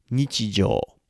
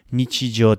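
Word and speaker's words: nichijō: The jō here, the long o sound, is the jō in nichijou. So it's the everyday, the frequency of everyday.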